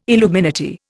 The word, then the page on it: illuminati: Indian